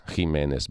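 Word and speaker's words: Giménez: E, e sempre dalla stampa, sfogliandola insieme, ci sono due pagine e un'intervista alla giallista spagnola Alicia Jiménez Bartles, mm, Alicia Jiménez Bartles per essere precisi, ma io non mi rassegno, è nata a Barcellona quindi non si pronuncia alla, alla, alla castigliana,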